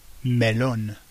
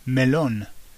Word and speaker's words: melón: Next one, melon is melón, melón. So it's the same, isn't it? Just emphasis on the o at the end, melón. Okay.